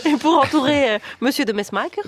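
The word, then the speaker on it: de Mesmaeker: Ouais, là, vous prononcez comment votre personnage? – Bah, moi, je disais de Mesmaquer, comme tous les Français. – Et alors, en Belgique, c'est de Mesmaquer.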